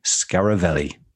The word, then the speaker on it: Scaravelli: Kim Scaravelli.